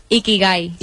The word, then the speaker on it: Ikigai: Ikigai.